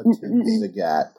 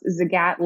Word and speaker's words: Zagat: Yeah, which is how Zagat pronounces his name, right? – Correct, yeah. We often tell people it's Zagat like the cat. It's rhymes with cat.